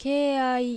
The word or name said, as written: Keiai